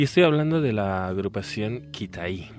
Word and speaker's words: Kitai: es Kitai, Kitai.